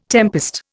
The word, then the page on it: tempest: Indian